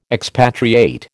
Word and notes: expatriate: American English

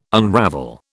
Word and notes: unravel: British English

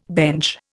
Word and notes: bench: Indian

